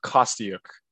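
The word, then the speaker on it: Kostyuk: Let's do Bencic versus Kostiuk.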